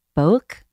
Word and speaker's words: Boak: It's technically pronounced Boak, like one syllable.